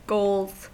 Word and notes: goals: American English

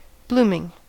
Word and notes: blooming: American English

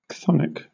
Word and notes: chthonic: British English

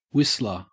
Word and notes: whistler: Australian English

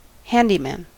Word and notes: handyman: American English

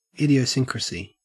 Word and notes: idiosyncrasy: Australian English

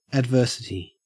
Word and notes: adversity: Australian English

